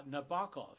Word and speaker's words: Nabokov: Nabokov. It rhymes Nabokov. With knockoff.